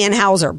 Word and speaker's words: Anheuser: Am I saying it right? – Anheuser. You got it right now. – I got it right now. I want to say Anheuser, but it's Anheuser-Busch of a key metric.